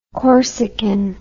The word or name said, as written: corsican